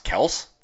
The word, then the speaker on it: Kelce: Travis Kelce told us is, it's actually supposed to be pronounced Kels this entire time.